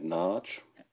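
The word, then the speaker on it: Nagy: Matt Narch. Ich glaube, das I am Ende spricht man nicht, ne? Wenn man es ungarisch spricht. Ja, genau. Also, also Ungarn auch das immer weggelassen.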